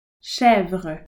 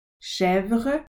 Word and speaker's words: chèvre: souris, mouse, lapin, rabbit, chèvre, goat, mouton, sheep, vache, cow, chien, chat, souris, lapin, chèvre, mouton, vache.